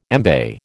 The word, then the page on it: embay: American English